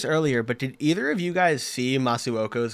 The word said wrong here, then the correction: Masuaku: goal against Chelsea? Or Masuaku, my bad on the pronunciation.